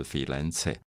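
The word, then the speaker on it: Firenze: 现在很多朋友到佛罗伦斯旅行啊，我一直喜欢把佛罗伦斯翻译成真正意大利文的 “filenze”，就是翡冷翠。我觉得“ 翡冷翠” 就翡翠中间加一个“ 冷” 这个字是最好的翻译，因为意大利文发音就是 “filenze”。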